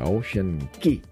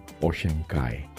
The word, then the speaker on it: Ocean Cay: Ocean Kai, o como usted quiera decirle, Ocean Ki, realmente es Kai, la pronunciación correcta.